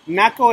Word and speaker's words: Naco: Naco is like spell it. N-A-C-O. – Oh, okay, that's what it sounds like.